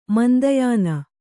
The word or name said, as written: ಮಂದಯಾನ